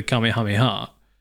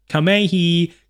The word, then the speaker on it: Kamehameha: Uh, and they do a power move, which in Dragon Ball Z is called the Kamehameha. Um, but there's loads of like young content creators who have never seen Dragon Ball Z and are just mispronouncing the absolute ever living hell out of it.